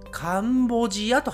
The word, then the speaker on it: カンボジア: はい、えー、英語ですとカンボディアと言っていますが、日本人は「カンボジアと発音します。カンボジアと言います。